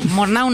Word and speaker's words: Murnau: Sí, Murnau no sé si se dice así. – Sí, sí, porque hay gente que lo francesa y no es el caso.